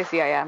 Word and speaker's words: CIM: Did you find that, so when you qualified for the trials at the, do people call it CIM? Is that, I mean, or, yeah. – Okay, is that how you say it? – I say CIM.